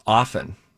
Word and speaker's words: often: I think technically it's often. – Mm-hmm. Okay. – I don't think you're supposed to pronounce the T.